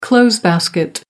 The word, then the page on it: clothes basket: American English